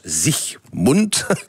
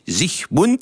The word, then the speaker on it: Siegmund: Alors, l'homme fort qui a permis cette victoire, c'est Ulrich Siegmund, en allemand ça se prononce Siegmund, pas d'algage, j'ai pas dit Sieg Heil, j'ai dit Siegmund.